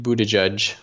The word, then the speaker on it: Buttigieg: Yeah, P Pete Buttigieg. It's spelled Buttigieg. Gieg, Buttigieg.